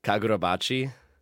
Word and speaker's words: Kagurabachi: Kagurabachi.